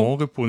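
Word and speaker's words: Monrepos: Mhm ich hatte mich kurz gefragt, wie es ausgesprochen wird, aber ich hatte so die Vermutung, dass irgendwie französisch ausgesprochen wird, ne? – Ja. Wird es auch. Ja, genau, Mon deswegen Repos. Mon Repos, nicht ja.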